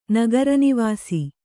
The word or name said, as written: ನಗರನಿವಾಸಿ